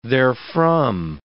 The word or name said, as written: therefrom